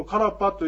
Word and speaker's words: karpa: このカラパというやつもどういうふうな文字になるかというと、ローマ字で書きますと K-A-R-P-A ですね。 R の発音ですね。 K-A-R-R-P-A、カラパですから、カラパじゃなくてカラパというふうに言うと、これは発音できます。